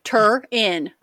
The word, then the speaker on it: Turin: So, like, they're not a big believer in Jesus on toast or the Shroud of Turin. I've never been able to pronounce that word. – Well, now you can. Turin. Be Turin. Like, Rin's turned up.